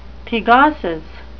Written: thegosis

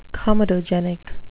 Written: comedogenic

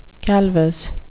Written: calvous

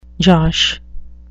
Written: josh

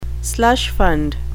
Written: slush fund

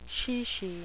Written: chichi

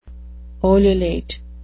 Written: ululate

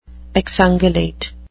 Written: exungulate

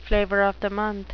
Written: flavor of the month

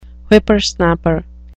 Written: whippersnapper

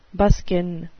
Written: buskin

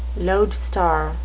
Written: lodestar

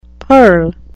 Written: purl

